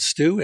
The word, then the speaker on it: Stu: My name is spelled Stu, S-T-U, and Heinecke, H-E-I-N-E-C-K-E.